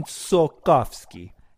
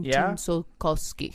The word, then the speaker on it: Tsiolkovsky: So it is. That's how you say his last name. Konstantin Tsiolkovsky. – Konstantin Tsiolkovsky. – Pretty good. – Thank you. – I actually spelled it out. So it's Tsiolkovsky.